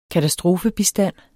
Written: katastrofebistand

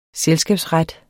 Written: selskabsret

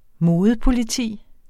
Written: modepoliti

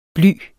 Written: bly

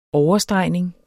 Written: OVERSTREGNING

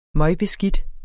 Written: møgbeskidt

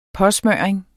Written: påsmøring

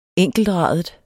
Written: enkeltradet

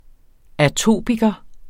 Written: atopiker

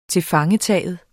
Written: tilfangetagen

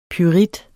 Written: pyrit

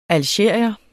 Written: algerier

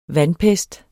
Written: vandpest

